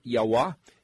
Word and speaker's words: Yahweh: But then he says, tell you what, Yud He Vav He, Yahweh, is one way to pronounce it. Yahweh is my memorial name, my Shem of Zakar, which is to say masculinity and to perpetuate in memoriam from generation to generation.